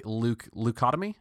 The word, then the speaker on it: leucotomy: So, and aggression and violent patients can be treated with a limbic leucotomy. Is that how it's pronounced? – Yeah, you got that.